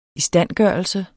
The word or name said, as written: istandgørelse